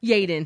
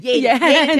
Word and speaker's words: Yeadon: Did I pronounce it correct? – Yeadon. – Yeadon.